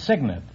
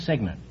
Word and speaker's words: cygnet: No, it's signet. It's spelled C-Y-G-N-E-T, but it's pronounced signet.